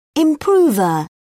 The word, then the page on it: improver: British English